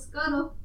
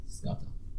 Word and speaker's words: Scudder: S-C-A-T. – No, it's S-C-U-D-D-E-R. – Scudder. – Scudder. – Scudder. It sounds like gutter.